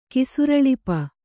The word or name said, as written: ಕಿಸುರಳಿಪ